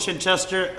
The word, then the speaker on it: Chichester: Chichester, but close enough. – No, we'll go with Chichester.